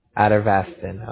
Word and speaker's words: atorvastatin: A-T-O-R-V-A-S-T-A-T-I-N. – Atorvastatin.